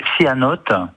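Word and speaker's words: céanothe: J'ai un cyanote, c'est bien comme ça se prononce.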